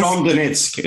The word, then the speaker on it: Donetsk: Donetsk.